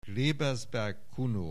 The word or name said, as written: Klebelsberg Kuno